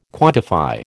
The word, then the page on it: quantify: American English